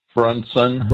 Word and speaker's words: Brunson: Is that his name? – Brunson. – Brunson. I never can get his name right. Brunson. – Yeah, like Brunson, yeah. Like a Bunsen burner, a Brunson burner.